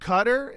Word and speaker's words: Qatar: It's actually pronounced Qatar, and when I say Qatar, usually someone corrects me.